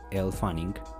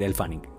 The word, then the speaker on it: Elle Fanning: si se dice Elle Fanning, mire, estoy pronunciando mal, ok, que, los que ponen los comentarios que pronuncio mal, estoy pronunciando mal, Elle Fanning, pero tú vas a decir El Fanning.